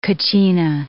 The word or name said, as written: kachina